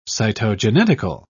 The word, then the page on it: cytogenetical: English; British English